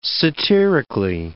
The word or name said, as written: satirically